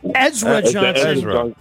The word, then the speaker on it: Ezra Johnson: All right, and I'm not sure if I'm going to pronounce his name right. Zar Johnson. – Oh, Ezra Johnson? – Ezra Johnson.